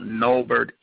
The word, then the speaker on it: Nolbert: So to follow me, you can go to my website, and my name is spelled Nolbert, N-O-L-B-E-R-T, Brown, Jr., and .com.